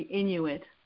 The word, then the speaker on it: Inuit: Kind of like, and then it's one of those, I don't know how many words there are in the, Intuit, is that who the people are, the Eskimo kind of people, like 50 words for snow, how do you say it? – The Inuit.